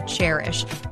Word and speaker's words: Chairish: And this week's No Limits Entrepreneur is Anna Brockway, who is the co founder and president of Chairish. And just so you know, it is spelled like a chair, C H A I R ish because it is a curated online marketplace for buying and selling vintage and used furniture.